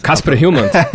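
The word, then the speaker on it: Kasper Hjulmand: A pronúncia estava me ensinando. – Kasper Hillmant. – Eu perguntei antes da gravação como é que é a pronúncia.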